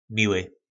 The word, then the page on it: mily: po nasimu